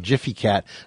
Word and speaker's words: Gfycat: He is the CEO and co-founder of Jiffy Cat. And that is spelled, by the way, G-F-Y-C-A-T. And it soft G, Jiffy Cat.